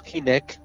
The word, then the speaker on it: Hynek: Halen, Joseph Hallen Heineck, o Hynek, como se pronuncia correctamente, porque de origen eh, me parece checoslovaco, eh, y, mucho más gente que trabajó, como el astrofísico Jacques Vallée, el gran Jacques Vallée.